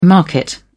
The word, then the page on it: market: British English